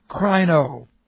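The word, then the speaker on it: krino (κρίνω): And as I said, we could spend a great deal of time studying this word in the Bible, K-R-I-N, long O, krino.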